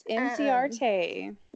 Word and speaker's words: Inciarte: Yes, Inciarte.